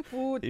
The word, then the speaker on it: Putin: So this is in 2016 Vladimir Putin pulled Steven say it right. – Right. Putin. He pulled Steven Seagal aside and said, "You're now a Russian citizen.